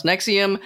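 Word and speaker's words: NXIVM: Though if you see it written out, it looks like NX. IVM, but it's pronounced Nexium.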